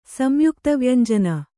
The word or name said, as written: ಸಂಯುಕ್ತವ್ಯಂಜನ